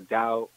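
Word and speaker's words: Tao: Actually, the original name of the art was called the Dao of Jeet Kune, T-A-O. T-A is pronounced Dao. – Okay. – D-A-O. T-A-O is, so it was the Dao of Jeet Kune. Dao is way in Cantonese.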